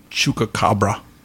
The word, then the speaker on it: chupacabra: Chupacabra.